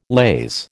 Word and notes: laze: American English

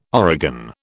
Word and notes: origan: British English

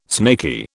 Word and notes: snaky: British English

